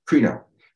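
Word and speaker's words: krino (κρίνω): Okay, the word is pronounced krino is the best, or krino, or krino. I would say kri, because it's got phonetically two E's. Okay, so it's kri, krino.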